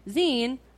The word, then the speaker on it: zine: My favorite thing in the zine is how you say zine rhymes with bean.